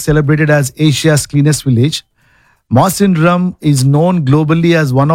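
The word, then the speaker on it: Mawsynram: and I'm pretty sure we are not pronouncing it right. – You are not pronouncing it right. – Oh, do you think so? – Because I went to YouTube and said how to pronounce. – Oh. – It's Masandrum.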